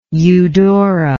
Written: eudora

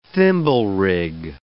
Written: thimblerig

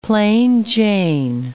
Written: plain Jane

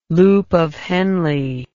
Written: loop of Henle

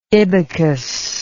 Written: ibycus

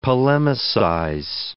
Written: polemicize

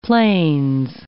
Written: Plains

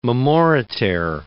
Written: memoriter

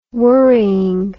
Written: worrying

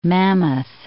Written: Mammoth Cave

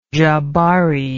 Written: jabari

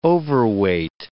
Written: overweight

